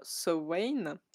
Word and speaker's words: Samhain: Du coup, oui, petit rappel pour ceux qui ont raté l'épisode sur sa main, l'épisode d'Halloween, ou plutôt l'épisode sur Samhain, si on le prononce correctement à l'irlandaise.